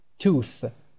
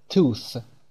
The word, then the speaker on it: tooth: اگر به صورت خودتون، به دهان خودتون در آینه نگاه کنید وقتی که کلمه tooth رو تلفظ میکنید اطراف لب و دندون و زبون همین شکل رو درست میکنه. tooth، دندون در وسط، زبون هم در وسط دندون ها میاد.